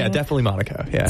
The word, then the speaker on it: Monaco: Another one is, Monaco, not Morocco, Monaco.